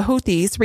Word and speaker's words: Houthis: The Houthis, I think that's how you say it, the Houthis.